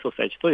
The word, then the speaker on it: ソサイチ: はい、あのソサイチはですね、ブラジル発祥の 7 人制サッカーでして、語源はあの社交的サッカーを意味するフットボールソサイチを、ポルトガル語の発音で略してソサイチと呼ばれています。